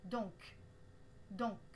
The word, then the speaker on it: donc: Pensez à prononcer le C à la fin comme un K donc, donc.